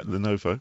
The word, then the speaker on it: Lenovo: I don't know if I've pronounced that right. It's Lenovo? – Yeah, Lenovo? – Perfect, yeah. Lenovo.